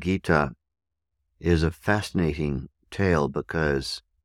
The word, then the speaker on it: Gita: Bhagavad is spelled B-H-A-G-A-V-A-D. Bhagavad. B-H-A-G-A-V-A-D. Bhagavad. Second word, Gita. G-I-T-A.